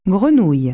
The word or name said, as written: frog